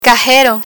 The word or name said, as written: cajero